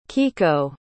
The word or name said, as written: kiko